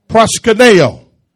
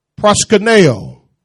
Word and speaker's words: proskuneo: Proskuneo. Amen. Proskuneo. Proskuneo. That's how it's pronounced in the Greek.